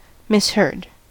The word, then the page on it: misheard: American English